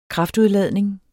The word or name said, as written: kraftudladning